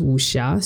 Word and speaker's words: wuxia: Oh, by the way, the term is pronounced wuxia. So wuxia. Anytime thank you. – X in like yeah, pinyin, mm-hmm. like Chinese pinyin is always pronounced like SH in English. Awesome. Similarly, if it's there's a letter just C, then it's pronounced like TS in English.